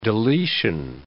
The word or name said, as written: deletion